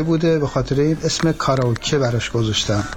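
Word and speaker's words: کاراکی (カラオケ): در مورد کاراکی باید بگم که تلفظ شما کاملا درسته. من به عنوان ایرانی که 26 ساله تو ژاپن زندگی میکنم، کاراکی همونی که شما میگین درسته.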